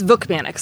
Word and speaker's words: Vukmanic: It's Vukmanic, so it's Vuk like book, and then manic like...